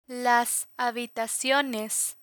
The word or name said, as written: habitaciones